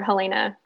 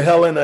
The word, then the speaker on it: Helena: If you come to Helena and say Helena, they'll say, uh-oh, there's another down south or east coast or whatever. So let's start pronouncing Helena.